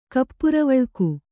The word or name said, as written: ಕಪ್ಪುರವಳ್ಕು